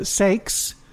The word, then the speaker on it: Saix: And then the Sakes is the other recorded project, and that's spelled S-A-I-X.